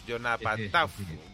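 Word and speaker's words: Yoknapatawpha: Y la zona esa que decías de la parte más africana del blues, Joknapat Taufa, teniendo en cuenta que eh, en la parte de PHA se pronuncia como Fa, pues Jonapa Taufa.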